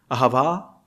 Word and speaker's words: Ahava: That's how you say that. Ahava. It's just where you put the pronunciation.